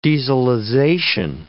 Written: dieselization

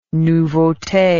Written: nouveaute

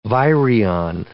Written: virion

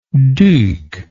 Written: duc

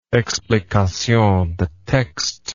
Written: explication de texte

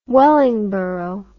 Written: Wellingborough

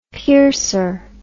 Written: piercer

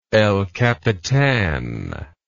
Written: El Capitan

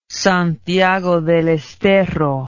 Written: Santiago del Estero

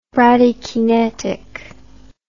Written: bradykinesis

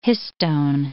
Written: histone

